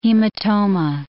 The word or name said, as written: hematoma